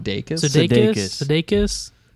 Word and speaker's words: Sudeikis: Jason, I guess you could say Sudeikis. Is that how you say his last name? – Sudeikis.